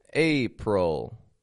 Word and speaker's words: April: April. That word is ending in this sound like ul. However, it's spelled with an IL. Why is that? It sounds like a UL.